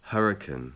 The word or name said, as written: hurricane